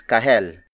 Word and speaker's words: kahel: Another Tagalog word for orange is kahel.